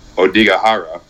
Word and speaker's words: Odaigahara: Third of May and o- Odaigahara, which is how you pronounce it, by the way. It's a mountain range in Japan.